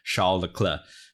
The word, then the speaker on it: Charles Leclerc: 他他他自己他自己有时候自己自称 Charles，所以说要不，但是就是理论上啊，法语在摩纳哥在法语区应该读成 Charles Leclerc。